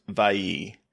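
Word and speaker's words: Viy: It's V-I-Y. It's on Shudder, but I believe it's pronounced Vayi.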